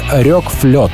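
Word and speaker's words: RökFlöte: Так что альбом правильно произносить как «рёк флёт», что-то типа того.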